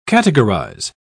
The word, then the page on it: categorise: British English